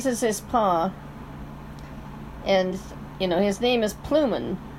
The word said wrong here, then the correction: Plumpin: if I'm saying that right. – Plumpin. – Plumpin.